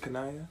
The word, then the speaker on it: Kaniya: Do you pronounce it Kaniya? – Yep.